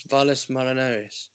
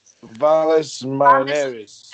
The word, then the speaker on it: Valles Marineris: Valles Marineris. – Valles Marineris.